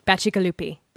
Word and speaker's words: Bacigalupi: Bacigalupi. – Bacigalupi.